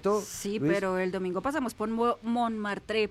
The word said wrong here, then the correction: Montmartre: perdón mi pronunciación del francés, ya sé que así no se pronuncia. – Montmartre.